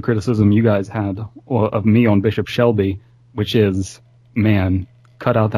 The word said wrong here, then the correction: Selby: Is that how it's pronounced, by the way? Bishop Shelby? – Selby.